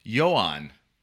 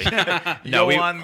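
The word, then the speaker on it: Ioan: I just found out how to pronounce it. It's Johan. – So Ian Gruffudd is in this movie. – Johan Gruffudd.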